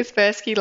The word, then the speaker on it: Kaspersky: Kaspersky, that's it. – Kaspersky Lab have recently released a report called Growing Up Online with some research that you guys did.